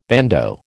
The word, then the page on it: bandeau: American English